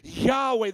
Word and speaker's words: Yahweh: It can be appropriate, but the actual word is Yohevah, Yahweh, the great God of Israel. That is how the Hebrews would have pronounced it.